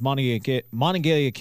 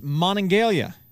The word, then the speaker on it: Monongalia: Couldn't pronounce Monongalia. By the way, okay. Seriously. I understand Monongalia is kind of a funny word, and, and it often gets confused with Monongahela I mean, come on.